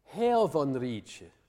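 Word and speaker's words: heofonrice: Then we have this word here, hervonriche. Now, notice that I'm pronouncing the f in the middle of the word, the letter f, I'm pronouncing it as a v, a voiced sound.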